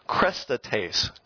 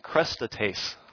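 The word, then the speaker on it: krestates (χρηστότης): In the Bible, when it talks about kindness of believers and the kindness of God, the word in Greek is pronounced krestates. Go ahead and say that one with me, krestates.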